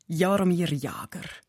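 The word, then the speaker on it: Jaromír Jágr: der Jaromir Jager.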